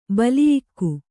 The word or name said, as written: ಬಲಿಯಿಕ್ಕು